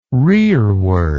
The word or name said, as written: rereward